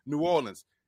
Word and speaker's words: New Orleans: That's how you pronounce it, New Orleans. And now some people, like, again, the local, you can tell who's from New Orleans and who's not, because the locals say New Orleans.